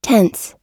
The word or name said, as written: tense